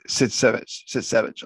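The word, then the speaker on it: Sytsevich: Paul Giamatti plays, well, I don't even know how to say this name, Alexis Sertovich. – Yeah, it's, yes, Sitsevich. Alexis Sitsevich, and he's not good in this.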